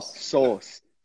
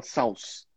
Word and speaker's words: sauce: Sós. Sós. A gente não pronuncia aquele U, a gente não fala sauce.